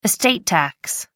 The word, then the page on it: estate tax: American English